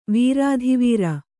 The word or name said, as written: ವೀರಾಧಿವೀರ